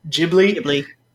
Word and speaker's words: Ghibli: So studio, is it Ghibli? – Ghibli.